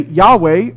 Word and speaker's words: Yahweh: And so, in English, we say Yahweh because Yah-wah sounds like Yahweh.